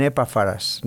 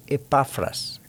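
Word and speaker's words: Epafras: Algunas veces he oído a personas que dicen Epáfras. No, es Epáfras, ¿okay? La correcta pronunciación.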